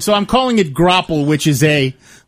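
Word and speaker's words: graupel: It's grapple.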